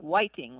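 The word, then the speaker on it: Hwaiting (화이팅): It's often spelled H-W-A-I-T-I-N-G or P-A-I-T-I-N-G. And it sounds like three syllables instead of two. So it might be like Hwaiting or Paiting, like that. – Yes. And sometimes it sounds like Whiting, like with a W almost.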